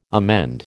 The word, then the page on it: amend: American English